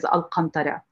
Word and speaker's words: Alcantara: And the pronunciation in Arabic is Alcantara.